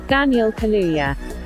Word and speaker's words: Daniel Kaluuya: Daniel Kaluuya.